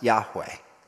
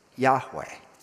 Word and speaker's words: Yahweh: Most likely pronounced Yahweh. Yahweh.